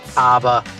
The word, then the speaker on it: ABBA: ABBA.